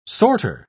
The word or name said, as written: sorter